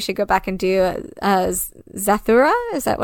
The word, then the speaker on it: Zathura: Yes, Zathura, that's how you pronounce it.